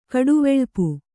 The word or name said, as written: ಕಡುವೆಳ್ಪು